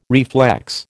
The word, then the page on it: reflex: American English